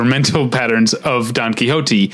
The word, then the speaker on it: Quixote: That's how you say it. – Quixote. Yeah, it took me a long time to figure that out.